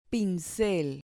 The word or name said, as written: pincel